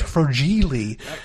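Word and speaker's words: fragile: I think it's Italian, it's pronounced Frogili.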